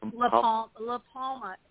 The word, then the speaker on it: La Palma: Palma? What's the name of that volcano? – Yeah, La Palma.